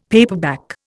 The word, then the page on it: paperback: Indian